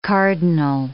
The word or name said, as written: cardinal